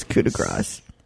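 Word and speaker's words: coup de grâce: Yes, it is coup de grace. – I'm sorry about that. And the funny thing is, if somebody had asked me about that, are you sure of that pronunciation? A week ago, I would have said, oh, yes, coup de grace. But now I'm looking at it.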